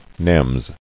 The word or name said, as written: Nems